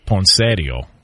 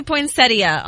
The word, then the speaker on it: poinsettia: Can we get the proper pronunciation on this? – It does say poinsettia online.